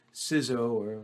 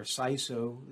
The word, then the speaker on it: CISO: You might hear it pronounced CISO or CISO. There are different ways to pronounce the acronym, but either way, it's the role within an organization for somebody to be looking at information security as a risk management issue.